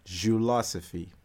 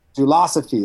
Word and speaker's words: Jiu-losophy: Jiu-losophy. – Jiu-losophy. It's like Jiu-Jitsu philosophy.